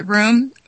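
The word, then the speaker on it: Röhm: And we were talking about, a lot of things, particularly about, and you pronounce it the correct way, room. I know the O with those two little dots is usually pronounced like a U, correct?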